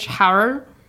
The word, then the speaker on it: Heinrich Harrer: And it is about an Austrian mountaineer named Heinrich Harrer, H A R R E R. Harrer, named Heinrich Harrer. That's how they say it. It's how German works. It's about white mediocrity.